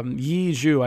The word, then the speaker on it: Yi Zhu: So it's like really dramatic, because like the whole point, it starts out with the main character, Yi Zhu. I can't pronounce that at all. That's the problem with Chinese anime. I can't pronounce anything. – No, that's how you say it. You did it right.